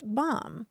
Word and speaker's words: BOM: Uh, the acronym for it is BOM, pronounced bomb.